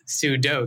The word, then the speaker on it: sudo: But I guess it is sudo because it's super user do or whatever.